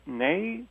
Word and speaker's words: née: You pronounced it née. Is it née? – Yes. Yes, it is. It's from French. And it simply means born in French.